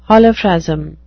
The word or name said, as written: holophrasm